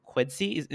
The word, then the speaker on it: Quidsi: So why did you decide to sell Beauty Bar to Quidsi? Am I pronouncing it right? – No, you're pronouncing it correctly.